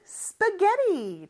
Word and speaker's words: spaghetti: 스파게티의 영어 발음은 spaghetti입니다. 영어는 높낮이가 있다고 했잖아요. spa 내려갔다가 getty 이렇게 발음해줘야 돼요. 그리고 마지막 요 t, 물론 t가 두 개지만 영어는 보통 자음이 두 개 있으면 하나만 발음해주거든요. 그래서 하나 있다라고 가정을 했을 때 T 발음은 모음 소리 사이에 껴서 이것도 리을처럼 발음이 돼요. 아주 짧게 해줘야 돼요, 대신. Spaghetti, spaghetti 이렇게 발음합니다.